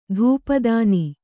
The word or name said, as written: ಧೂಪದಾನಿ